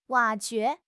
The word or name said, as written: 挖掘